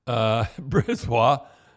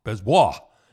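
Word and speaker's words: BriseBois: What he meant by then, I have to give the GM, Brisbois, I think that's how you pronounce it, Brisbois.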